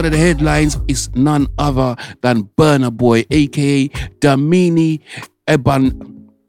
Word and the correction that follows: Damini Ebunoluwa Ogulu: Let me put some respect on his name. Let me say his name right. Damini Ebunoluwa Ogulu, known professionally as Burna Boy, is a Nigerian singer, songwriter, and a record producer.